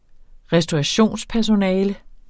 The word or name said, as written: restaurationspersonale